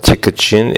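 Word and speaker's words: Tykocin: And it was, he was written a letter about a get that was given in the ear Tiktin. Right? That's the way they pronounce it. We today call it Tikachin.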